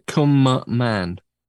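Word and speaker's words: Cwmaman: CWM. – Come. – A-M-A-N. Come on, man. C-W-A-M-A-N. Come man. – Come man, wasn't it? – Come on, man. – C-W-M is definitely come, so it's come.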